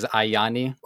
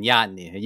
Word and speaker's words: Ianni: When somebody asks me, as you did, how people to pronounce my last name, it's either standard as Ianni or the Shakespearean Ianni.